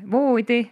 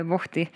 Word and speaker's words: voodi: Voodi, mitte vohti, sest me hääldame voodi kahe ooga.